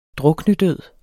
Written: druknedød

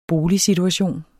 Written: boligsituation

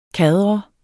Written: kadre

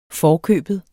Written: forkøbet

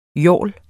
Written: yawl

